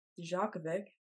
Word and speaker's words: Djokovic: The Roland Garros culminated in Novak Djokovic.